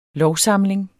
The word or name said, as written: lovsamling